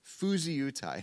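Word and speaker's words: phusiutai (φυσιοῦται): Phusiutai. And I shared that word with my wife this week, and she said it sounds kind of Japanese. And I checked the pronunciation, and that's how you say it.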